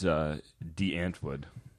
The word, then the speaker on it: Die Antwoord: I understand it's De Antwood is the pronunciation.